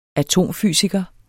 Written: atomfysiker

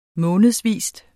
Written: månedsvis

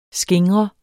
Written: skingrende

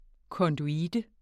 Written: konduite

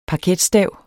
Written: parketstav

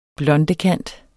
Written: blondekant

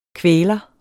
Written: kvæler

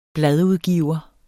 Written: bladudgiver

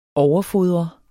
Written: overfodre

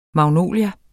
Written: magnolie

